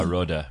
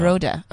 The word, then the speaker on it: Rohde: Is it Rodda? R-O- H-D-E. – Yeah, Rhoda. Yeah. – Rhoda, okay.